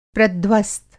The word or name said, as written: प्रध्वस्त